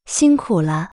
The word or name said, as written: 辛苦了